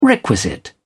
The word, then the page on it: requisite: British English